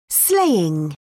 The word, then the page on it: slaying: British English